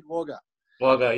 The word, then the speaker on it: Wagga Wagga: Spelt Wagga, said Wagga. – Wagga, yeah. Apologies for our pronunciation, so Wagga Wagga.